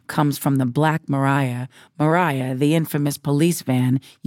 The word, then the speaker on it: Mariah: The story also goes that I was named after a hit 1950s show tune they call The Wind Mariah from Paint Your Wagon, a Broadway show about the California Gold Rush. Both references use the soft pronunciation with the second syllable having a ry sound.